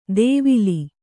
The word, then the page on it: ದೇವಿಲಿ: dēvili